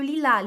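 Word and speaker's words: lilas: Aqui a gente está no feminino, né, em numerosas, em várias, fleur, flores, como le lilá. Lilá se escreve lilás, não tem acento, não tem acento em francês, tá? Não tem o um acento agudo igual a gente coloca em português e não se pronuncia o S, tá bom?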